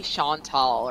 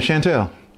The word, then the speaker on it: Chantel: Everyone calls me Chantal or Chantel. Chant- so it's yes. Yeah, Chantel.